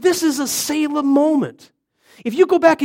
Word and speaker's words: Selah: is it Selah or Selah? Selah? I always say it wrong, then. It's a good thing I ask. So there's a number of places where it says Selah.